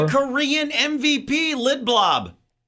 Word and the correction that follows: Lindblom: You can't even say his name right. – What is it? Lindblom? Lindblom? – Lindblom.